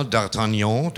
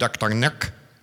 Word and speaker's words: D'Artagnan: Vor einiger Zeit sagte ich einmal D'Artagnan, denn so wird jene Kapelle ausgesprochen, nicht D'Artagnac, wie ich auch erst dachte, weil man so oft liest und so wenig gesprochen hört.